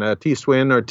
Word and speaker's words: Tiswin: is Tiswin. And maybe you can explain how that fits into the culture a little bit. – Well, yes, and you pronounced it just fine, Tiswin, they call it in Mexico, and farther south in Latin America, they call it chicha.